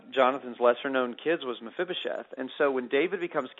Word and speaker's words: Mephibosheth: And you talk about Mephibosheth. I think I'm saying his name right. Talk about that one. – Well, yeah, this is the fun chapter where I always love doing these interviews, because it's Mephibosheth.